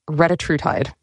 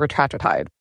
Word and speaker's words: retatrutide: It is, I believe, pronounced retatrutide. – Mm-hmm. – I want to call it retatrutide.